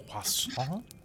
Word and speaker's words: croissant: Whenever I said that in front of Teddy, and he kind of made fun of me. He said it's croissant.